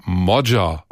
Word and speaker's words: Magyar: Sein Name schreibt sich zwar Peter Magyar mit Y, der Nachname wird aber Modja ausgesprochen.